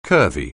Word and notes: curvy: anglais

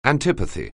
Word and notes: antipathy: anglais

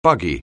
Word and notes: buggy: anglais

